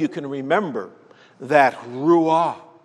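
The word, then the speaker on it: ruah (רוח): In English, we would spell it R-U-A-H, but it's supposed to be a very breathy sound, ruah.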